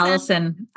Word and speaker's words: Allison: Allison. Correct. Yes. Yeah. And then they spell Allison wrong. Not the way I spell it, right? Obviously, there are many ways to spell Allison, but Same thing, two L's and an I, A-L-L-I-S-O-N.